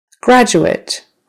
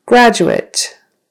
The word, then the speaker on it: graduate: Graduate. Graduate. So that's, the emphasis is on the first syllable. Graduate. That's if it's a noun. He is a graduate. However, if it's a verb, graduate, graduate.